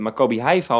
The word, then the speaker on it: Maccabi Haifa: Het is natuurlijk begonnen met die wedstrijd tegen Maccabi Haifa, als ik het goed zeg.